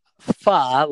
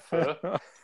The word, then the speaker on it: pho: It's pho, like fun, with the N taken off the end of it. – You sure it's not pho? – I don't know what that is.